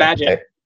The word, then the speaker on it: Bagehot: How do you pronounce that? – Bagehot. You got it.